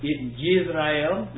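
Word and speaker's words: Yisrael (ישראל): Yisrael is how you pronounce it.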